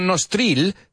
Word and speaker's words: nostril: N-O-S-T-R-I-L. No nostril, se pronuncia nostril, como si esa I fuera una E.